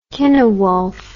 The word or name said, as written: Kynewulf